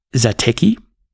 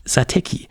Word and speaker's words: Satechi: Ich sag mal, für mich steht da Tech am Ende, wie Technology und Satechi. Und deswegen sage ich Satechi.